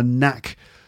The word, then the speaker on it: knack: By the way, the word knack, to have a knack for something, knack is spelled K-N-A-C-K, okay? Knack. So it's not pronounced knack, it's pronounced knack. All right, it's one of those words with a silent K at the beginning, like knowledge or know, in this case, knack, all right?